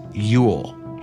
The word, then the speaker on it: geol: The word Yule, like so many of our Christmas traditions, comes from an old Anglo-Saxon word spelled G-E-O-L and pronounced, you guessed it, Yule.